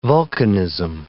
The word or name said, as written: vulcanism